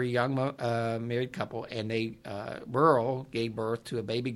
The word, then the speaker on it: Beryl: B-E-R-Y-L. How would you say that? – Burl. – Burl, okay. – You said it right.